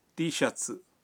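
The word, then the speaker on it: ٹی شٹس (Tシャツ): ٹی shirt کا جاپانی تلفظ ہے ٹی شٹس۔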